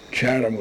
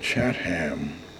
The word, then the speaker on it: Chatham: When I first came to Chatham in the early days, my boyhood days, Chatham was not pronounced that way. It was always called Chatham, like Wareham, like Eastham.